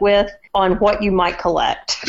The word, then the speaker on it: Collect: Collect is a bit of a funny-sounding word, but it is spelled the same way as collect, like you may collect Barbie dolls or Star Wars action figures.